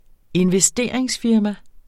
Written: investeringsfirma

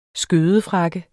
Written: skødefrakke

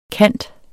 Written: kant

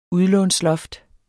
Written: udlånsloft

